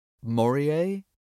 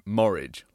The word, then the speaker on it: Morije: It's spelled M-O-R-I-J-E. Morier? Until very recently, we'd been pronouncing it Morridge, like porridge, even though we were almost certain this wasn't a name.